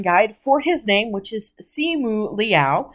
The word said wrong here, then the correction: Simu Liu: Simu Liu.